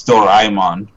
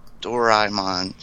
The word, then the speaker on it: Doraemon: It's Doraemon, by the way. – Doraemon? Doraemon.